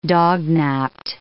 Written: dognaped

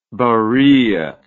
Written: barilla

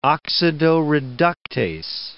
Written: oxidoreductase

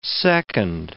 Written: second

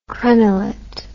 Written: crenelet

